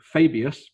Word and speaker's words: Fabius: So War of the Spider, which will introduce the new Fabius, because that's how you say his name, apparently,